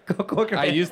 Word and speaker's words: Corcoran: How do you pronounce it? Corcoran? – Corcoran.